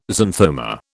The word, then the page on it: xanthoma: British English